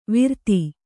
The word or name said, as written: ವಿರತಿ